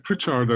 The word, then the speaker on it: Pritchard: Pritchard, I think, is the proper Welsh way to say it.